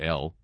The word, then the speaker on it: Elle: That's E-L-L-E, not L. But it's pronounced L. But it's not spelled L.